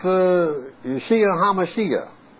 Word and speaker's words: Yeshua HaMashiach: It's pronounced Yeshua HaMashiach. That's how it's pronounced in the Hebrew.